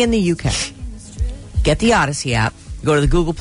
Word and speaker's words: Audacy: It's free, but it's spelled wrong. It's spelled AUDACY. Think audio, audacious, and then a musical journey, an odyssey.